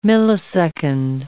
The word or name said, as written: millisecond